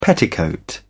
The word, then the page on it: petticoat: British English